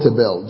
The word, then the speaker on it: tebel: Now, the word world is the Hebrew word tebel. Just put, we're going to call it tebel. T-E-B-E-L. Or pronounced as tebel.